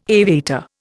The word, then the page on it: aviator: Indian